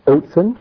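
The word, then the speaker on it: Otsin (אוזן): And it's pronounced Otsin, and it means ear.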